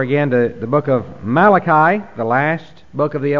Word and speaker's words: Malachi: This book has a variety of pronunciations. When we lived in Ireland, in the British Isles, people refer to this book as Malachi. And the name is still a common name in parts of Ireland. There are people who are named Malachi. And so you have it. I've heard one person even pronounce it Malachi. But we're going to call it Malachi, I think the way most of us have heard it through the years.